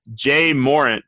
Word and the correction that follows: Ja Morant: right? – Ja Morant. – There you go.